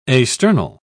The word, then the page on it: asternal: British English